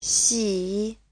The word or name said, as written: xi3